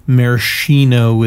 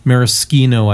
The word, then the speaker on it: Maraschino: So Maraschino is how we kind of pronounce it lately, but Maraschino, I think, is the right way. – Right. – It, at least it's the way Marilyn Monroe pronounced it.